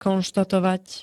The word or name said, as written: konštatovať